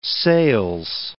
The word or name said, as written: sales